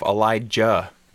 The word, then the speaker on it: Elijah: If you ever watch interviews with him, he calls himself Elijah with a hard J.